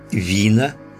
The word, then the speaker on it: вина: Среди священных болей есть некоторая группа, называемая «вина создателя». Примечание. «Вина» – санскрит – струнный инструмент в Индии и Тибете, ударение на букву «и».